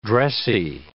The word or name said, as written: dressy